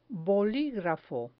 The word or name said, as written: boligrafo